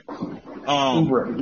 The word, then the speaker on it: Oubre: They got Kelly Oubre or Oubre. That's how you pronounce it, right? – Oubre, yeah.